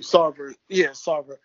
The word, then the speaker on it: Sarver: Robert Sarver or Robert Tarver? – Sarver. – Thank you. Sarver.